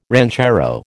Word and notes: ranchero: American English

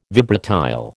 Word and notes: vibratile: American English

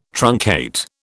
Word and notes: truncate: British English

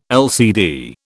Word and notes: LCD: British English

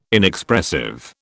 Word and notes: Inexpressive: British English

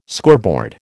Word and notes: scoreboard: American English

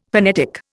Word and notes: phonetic: Indian

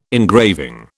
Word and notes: engraving: British English

